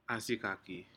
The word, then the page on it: asikaki: Aneityum